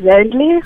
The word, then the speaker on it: Zandelee: Did I pronounce your name correctly or did I butcher it? – No, that's perfect. Zandelee.